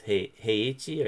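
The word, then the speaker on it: Heichi (平地): Is that correct? Heichi or heichi? – Heichi, yes. – Heichi? – Yeah, heichi. I'm more like a flat pronunciation.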